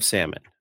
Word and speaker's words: salmon: You're actually saying it right now. That only took 10 years. I love it. – Salmon. There's an L in it for a reason.